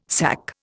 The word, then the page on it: SAC: Indian